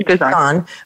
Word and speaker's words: Ghizon: He's in a culture in Ghizon. I may not be pronouncing it correctly. – Ghizon. Mm-hmm. Ghizon.